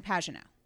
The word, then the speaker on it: Pagenaud: while I'm looking, the person that is leading the series right now is Simon Pagenaud.